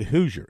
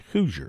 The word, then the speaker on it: Hauser: They pronounce it Hoosier, H A U S E R. Nolan Hoosier, like Hoosiers of Indiana.